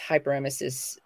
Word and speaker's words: hyperemesis: Cannabis hyperemesis. I'm probably saying that wrong syndrome. Somebody correct me. – Exactly. – Is that right? – Yep.